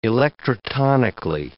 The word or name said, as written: electrotonically